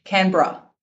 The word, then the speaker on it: Canberra: Say it in Australian. Canberra. – Canberra. There you go.